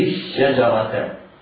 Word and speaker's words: الشجرة: لا تنطق وإنما ينطق الحرف الذي بعدها مشددا: ولا تقربا هذه الشجرة.